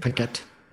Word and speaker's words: Paquette: Paquette? Is that how you say it? – Paquette.